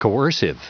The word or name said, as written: coercive